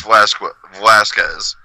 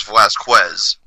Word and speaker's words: Velasquez: Like, I don't know if we were always pronouncing it wrong. – Listen, man, you know Republicans don't give a fuck how to pronounce somebody's name. – Man, like, Velasquez. Like, that just doesn't roll like Velasquez. – Yeah. – Like the Q is the K, you know?